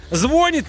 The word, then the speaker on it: звонит: Потому что ударение в слове «звонит» или «звонит» в будущем изменится и начнет падать на первый слог.